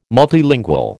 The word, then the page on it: multilingual: American English